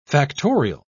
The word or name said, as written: factorial